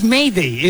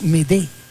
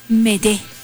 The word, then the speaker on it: Mayday: Entonces no es Mayday, es Medé. – Medé. A ver, dilo otra vez. – Medé. – Otra vez, otra vez. – Medé.